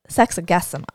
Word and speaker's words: sexagesima: So today we are discussing your sermon from this past Sunday, and both you and Vicar mentioned how Sunday was sexagesima. Yes. Am I saying that right? Okay. – I guess. Sure. – Okay. – I guess sima. Okay. – Gesima. Very good.